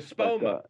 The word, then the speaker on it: Espoma: The growing medium at that time was something from Miracle-Gro, but I've since switched to Aposa for this year. Aposa? I can't pronounce it properly. – Oh, Espoma.